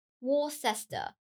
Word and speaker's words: Worcester: Oh, Worcester. – Oh, it's close. Yeah, Worcester. – It's not pronounced as it's spelled at all.